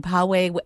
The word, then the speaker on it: Poway: Same thing for John in Poway, California. Is that the correct way to say that one? – Yep, P-O-W-A-Y? – That's right.